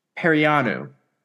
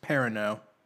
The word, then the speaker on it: Perrineau: E-A-U. – I want to say it's French. – E-A-U is typically O. – Perino? – Perino.